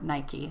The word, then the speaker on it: Nike: I'm not saying everybody just, you know, rejoice and throw your arms around him and start giving him Nike deals again. Or Nike.